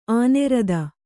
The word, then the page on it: ಆನೆರದ: ānerada